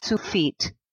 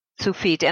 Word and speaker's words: Tsufit: Well, it's spelled T-S-U-F-I-T, and it's pronounced Sufit. Sufit.